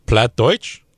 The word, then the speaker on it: Plattdeutsch: Um, you guys, you guys can can pronounce this better than I. Please feel free to. – Plattdeutsch. – There you go.